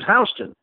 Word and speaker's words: Houston: And I know Houston is spelled Houston, but everyone in New York pronounces it Houston. And the reason for that is, you ever been to New York? That's the reason for that. People just decide 140 years ago that we're pronouncing it Houston.